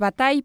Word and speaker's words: Bataille: Qué bonito pronunciaste Bataille, el nombre de Bataille, que a tantos nos cuesta tanto trabajo. Qué belleza.